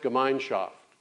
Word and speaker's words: Gemeinschaft: There's a German word. It's pronounced Gemeinschaft. Gemeinschaft, it's difficult to capture in one English word.